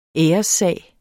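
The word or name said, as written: ÆRESSAG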